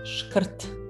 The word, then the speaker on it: шкрт: Скупой по-сербски шкрт. Шкрт. Здесь R носитель ударения. Шкрт.